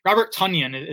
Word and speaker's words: Tonyan: I think the fringe guys, Robert Tonyan is how it's pronounced. I don't know if you saw that. It's not Tonyan, it's Tonyan.